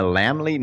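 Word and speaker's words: Laemmle: In LA, it opens at the Landmark and the Lamley NoHo, if I'm pronouncing that correctly. – Yes. Yes. – The Lamley.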